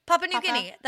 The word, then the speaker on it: Papua New Guinea: Papua New Guinea.